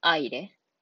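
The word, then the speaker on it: aire: 他は、アイレ。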